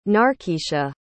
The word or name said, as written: narkeasha